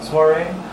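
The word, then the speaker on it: soirée: Am I saying that right? Soiree? – Oui. – Very good.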